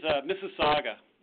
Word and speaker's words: Mississauga: The name of the city is Mississauga.